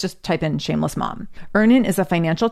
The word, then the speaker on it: Earnin: That's where Earnin has you covered. So all you need to do is go to either Google Play or the Apple App Store and download Earnin today. It's spelled E-A-R-N-I-N.